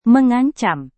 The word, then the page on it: mengancam: Indonesian